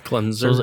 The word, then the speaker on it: klezmer: but it was K-L-E-M-E-Z-R Klezmer. – It was like klezmer.